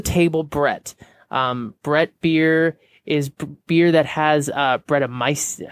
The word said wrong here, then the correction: Brettanomyces: I can never really pronounce it. Brettanomyces.